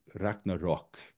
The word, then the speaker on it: Ragnarök: We have the word spelled A-R-G-N-A-R-O with a little hook under it, and then K. And if I remember my Old Norse pronunciation correctly, it's supposed to be pronounced Ragnarok. And then you have the version that Snorri Sturluson employs in Edda, which is spelled A-R-G-N-A-R-Ö.